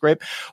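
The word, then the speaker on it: Greyp: Spelled weird, but it's pronounced Grape.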